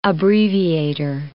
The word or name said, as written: abbreviator